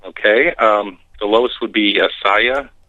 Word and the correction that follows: Asiyah: Slightly off on the pronunciations, but I'm not going to, hold it to that, level of specificity. I would say if I were to give the correct pronunciations as closely as I know them to be, it's Asiyah, Yetzirah, Briah, and Atzilut.